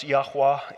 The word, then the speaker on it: Yahweh: The Hebrews did not often write with vowels. Pronounced Yahweh, it was called the Tetragrammaton.